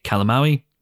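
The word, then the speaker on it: Calamawy: played by Mae Calamawi, which, as you'll soon hear, is the correct pronunciation of her surname; and of course, Mark Spector himself.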